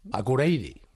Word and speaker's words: Akureyri: Akureyri. – Akureyri, más o menos.